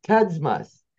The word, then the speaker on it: Kudzmas: Kudzmas.